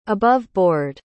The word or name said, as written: above-board